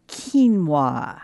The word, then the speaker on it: quinoa: This morning, I'm going to talk about quinoa. I'm wondering if you've seen that word. It doesn't look like that. It's spelled Q-U-I-N-O-A, and it's pronounced quinoa, Q-U-I-N-O-A, quinoa.